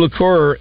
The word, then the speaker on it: liqueur: Yeah, and so they've teamed up with Sugarlands Distilling to launch a new liqueur. Is that the way you pronounce it? – Yes.